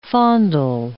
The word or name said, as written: fondle